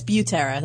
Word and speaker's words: Butera: Do I say your name right? – Butera. – I've been saying Butera. – That's how Howard Stern says it. And I don't correct the man, but yeah, it's Butera.